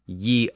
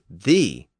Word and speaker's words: ye: And that technique gave us the spelling of Y-E for the, as in ye old tea shop. But the first word was always pronounced the, not ye. The letter Y was just used as a substitute for the thorn.